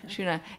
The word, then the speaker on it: Shuna: Is that how she pronounced it? – Yeah. – Shuna. It's just so you guys know, it's S-H-U-N-A, which is why I mispronounced it.